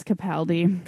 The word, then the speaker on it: Capaldi: It's Capaldi.